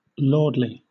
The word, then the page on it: lordly: British English